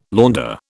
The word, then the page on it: launder: British English